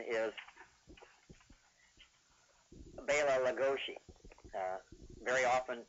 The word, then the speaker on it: Bela Lugosi: But that's the that's the correct pronunciation of his name. And very often, if you hear him at the beginning of a program, when he's introducing himself, uh, he will definitely put the accent on Bela.